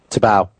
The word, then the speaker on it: Tebow: Tebow.